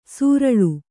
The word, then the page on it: ಸೂರಳು: sūraḷu